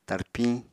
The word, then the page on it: tarpiñ: Apatani